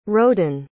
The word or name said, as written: roden